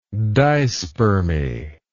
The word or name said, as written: dispermic